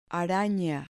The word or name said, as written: arana